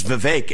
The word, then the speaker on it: Vivek: If I say my name's Vivek, it rhymes with cake.